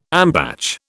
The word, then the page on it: ambatch: British English